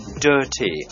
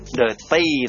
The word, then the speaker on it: dirty: D-I-R-T-Y ออกเสียงว่า dirty ไม่ใช่ dirty นะครับ dirty เน้นพยางค์แรกครับ